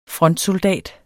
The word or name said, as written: frontsoldat